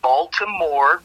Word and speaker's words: Baltimore: First, I'm from Baltimore, Maryland, but let me say it properly. I'm from Baltimore, Maryland.